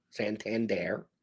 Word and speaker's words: Santander: Santander. That's how you pronounce the name.